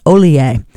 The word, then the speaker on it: Ollier: Looks like John Hollier, but it's a French name, so it's pronounced Ollier.